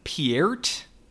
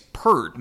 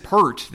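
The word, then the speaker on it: Peart: And I guess that would explain, I thought it was weird the St. Thomas guy was going Piert for, I mean, I'm assuming it's Pert. Maybe I'm just basic. I'm mainly going off of, I believe now late Rush drummer Neil Pert, which is spelled the same way.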